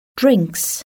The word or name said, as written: drinks